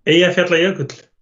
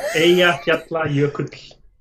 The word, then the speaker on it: Eyjafjallajökull: Eyjafjallajökull. – Yeah, it's that one. Yeah. – Eyjafjallajökull.